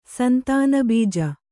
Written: ಸಂತಾನಬೀಜ

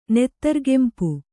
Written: ನೆತ್ತರುಗೆಂಪು

